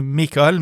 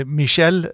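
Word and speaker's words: Micheál: Det altså ikke Michael, men mer som I det franske Michel, eller med en liten apostrof der, så ja, jeg skal ikke prøve å uttale på gellisk, men vi skal ikke gjøre den feilen igjen.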